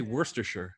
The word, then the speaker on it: Worcestershire: I don't even know how to say Worcestershire. – No, that's it. That's good. I just don't think you pronounce the R so much, like just Worcestershire.